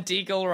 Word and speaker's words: Deagle: And Deagle rhymes with Beagle, which is a kind of dog.